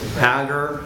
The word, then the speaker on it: Agur: One is known as the Oracle of Agur. That's how you say his name.